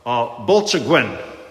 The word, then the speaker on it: Bwlchgwyn: B-W-L-C-H-G-W-Y-N. It's pronounced, as best as we can tell, Bolchaguen.